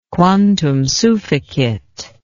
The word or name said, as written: quantum sufficit